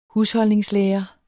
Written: husholdningslærer

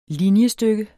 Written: linjestykke

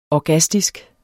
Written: orgastisk

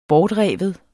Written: bortrive

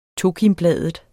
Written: tokimbladet

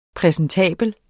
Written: præsentabel

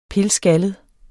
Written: pilskaldet

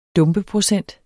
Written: dumpeprocent

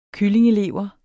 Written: kyllingelever

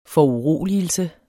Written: foruroligelse

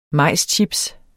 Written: majschips

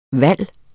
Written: VALGET

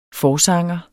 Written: forsanger

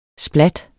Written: splat

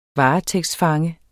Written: varetægtsfange

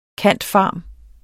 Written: cand.pharm.

